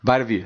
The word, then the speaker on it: Vita Vea: So it's going to be either Vita Vea. – Mm-hmm. – Am I saying it right? – Vita Vea.